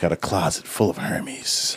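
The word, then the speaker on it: Hermès: The H is silent. – It is. Hermes.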